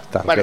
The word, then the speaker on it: Stargate: ¿Cómo se dice? Stargate, ¿no? ¿Se pronuncia? – Stargate.